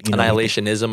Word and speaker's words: Annihilationism: For his family that does not know Christ, for his family that, you know, what annihilation is, and I hope I'm saying that right. – Annihilationism, I think, is right. Annihilation. There we go.